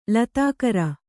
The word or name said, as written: ಲತಾಕರ